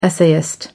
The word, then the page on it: essayist: American English